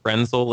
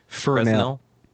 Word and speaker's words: Fresnel: And I always say this wrong. Fresnel lens? – Fresnel. – Fresnel. See, I screwed it up.